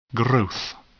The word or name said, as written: growth